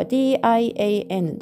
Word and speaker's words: 点: 発音記号は dian 第三声低く沈む音になりますー七ー七ンで 7 時という意味になりますその次も数字ですね。